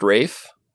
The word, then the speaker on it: Ralph: Ralph is pronounced Rafe.